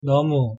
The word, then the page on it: 너무: Korean